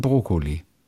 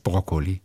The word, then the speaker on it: Brokkoli: Wo viele sagen Brokkoli. – Und wie heißt es richtig? – Brokkoli. Es heißt Brokkoli. Es heißt nicht Brokkoli.